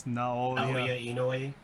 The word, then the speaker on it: Naoya Inoue: Naoya Inoue.